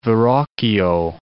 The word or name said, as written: Verrocchio